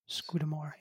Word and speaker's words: Scudamore: Scudamore? – Scudamore, yep.